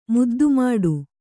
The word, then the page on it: ಮುದ್ದುಮಾಡು: muddu māḍu